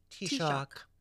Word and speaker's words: Taoiseach: It's pronounced Taoiseach.